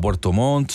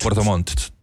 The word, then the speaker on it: Puerto Montt: No, y el calendario va a Puerto Montt, sobre sí, todo, sí. Y bueno, para Colo-Colo también. – Pronuncie bien, usted es locutor. – ¿De qué? – Puerto Montt. – Puerto Montt. Me faltó una T.